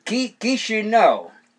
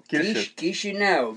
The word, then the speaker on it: Chișinău: Because I was learning the capitals, and it's like Chișinău or Chișinău or Chișinău. – Chișinău. – Chișinău.